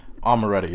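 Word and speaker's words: Omertà: Is it Amaretta? – Amaretta, yeah.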